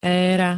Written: éra

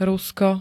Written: rúško

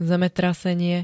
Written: zemetrasenie

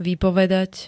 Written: vypovedať

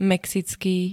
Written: mexický

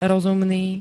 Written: rozumný

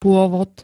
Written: pôvod

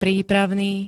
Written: prípravný

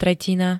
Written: tretina

